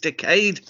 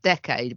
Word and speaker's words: decade: Well, my first contribution to the debate is that it's pronounced decade, but anyway, we move past that. – My mother, who's from Ireland, she used to say decad.